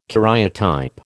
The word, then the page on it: karyotype: American English